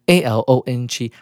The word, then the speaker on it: along: 那事实上，我后来补充这个字 a l o n g，它的发音应该念成 along。